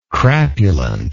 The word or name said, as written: crapulent